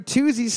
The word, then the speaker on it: Toosie: We're looking for Toosie Slide by Drake. Toosie Slide by Drake. Well, how the fuck do you spell that? T O O S I E. – Oh, okay, like Tootsie without the T.